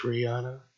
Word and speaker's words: Rihanna: How you say her name? – It's Rihanna.